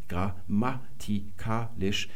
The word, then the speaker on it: grammatikalisch: Wenn wir nämlich das Wort mal skandieren, grammatikalisch, werden wir feststellen, dass auch auf dem Ma noch eine Betonung liegt.